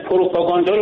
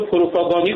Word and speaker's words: پروپاگاندا: حالا آقای رئیسی که خب پروپاگاندا رو پروپاگاندی قبلا گفته بود، لوکوموتیو لوکوموتیر گفته بود که مورد تمسخر واقع شد که ایشون اونقدر با تیر تفنگ سر و کار داره.